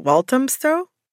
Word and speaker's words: Walthamstow: Like, you grew up in, I'm going to butcher the name, I'm going to try not to. Is it Walthamstow? – Wow, great.